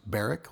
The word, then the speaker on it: Barak: You can also get a hold of us at B-A-R-A-K, which is pronounced Barrick, like Eric with a B, at FetLife.